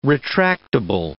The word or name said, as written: retractable